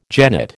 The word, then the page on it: genet: American English